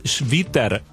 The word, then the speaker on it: Schwitter: Estamos en comunicación con Agostina Di Luciano y León Schwitter. No sé si digo bien el apellido pronunciado así. – Súper, genial.